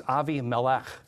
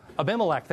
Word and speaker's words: Abimelech: I can say it better in Hebrew. It's Avi Melech. It's father king. Melech is king. Avi is father. But Abba, how do you say it in English? Abimelech, thank you.